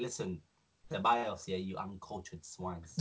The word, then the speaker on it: Ceballos: He's still in living, and putting Dani Ceballos.